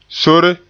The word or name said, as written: sore